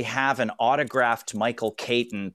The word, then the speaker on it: Caton: Caton, which is spelled C-A-T-O-N.